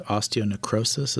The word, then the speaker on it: osteonecrosis: You have a degenerative disease called osteonecrosis.